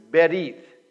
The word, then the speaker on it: Berith: The word is a Hebrew word, and it's pronounced Berith, B-E-R-I-T-H, Berith.